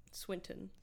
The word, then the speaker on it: Swinton: Am I pronouncing that right? – Swinton. – Swinton, forgive me.